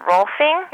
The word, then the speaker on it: Rolfing: Now, you say you often use Rolfing. Am I pronouncing it right? – Yes, ma'am.